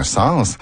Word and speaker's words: sens: En revanche, dans l'expression « ça n'a pas de bon sens », il est recommandé de prononcer l'S final de « sens ». Il suffit de penser à l'expression synonyme « ça n'a aucun sens » dans laquelle l'S final de « sens » est bien sonore.